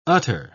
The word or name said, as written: utter